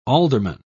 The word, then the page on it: ealdorman: British English